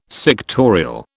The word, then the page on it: sectorial: British English